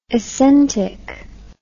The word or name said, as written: essentic